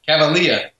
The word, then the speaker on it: Cavalea: Cavalea.